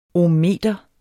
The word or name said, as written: ohmmeter